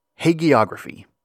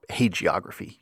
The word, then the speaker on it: hagiography: Which brings us to our next basic vocabulary item, hagiography, or hagiography. You'll hear both pronunciations.